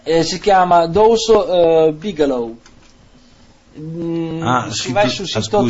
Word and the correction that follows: Deuce: Si dice Deuce Bigelow. – Scusami, c'è anche la ragazza che conosce le lingue, non sai come si pronuncia Deuce.